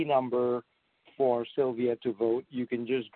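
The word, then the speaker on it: Sylwia: Makowska is M-A-C-K-O-W-S-K-A. And it's Sylvia is pronounced Sylvia, but it's S-Y-L-W-I-A. S-Y-L-W-I-A.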